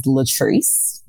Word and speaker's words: Latrice: My birth name is Latrice, right? Mm-hmm. And it's spelled L-A-T-R-I-C-E.